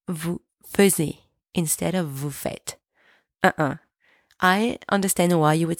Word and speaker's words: vous faites: Second, make sure the S from vous faites is silent. It's not vous faites. It's at the end of the word, so it's silent. It's vous faites.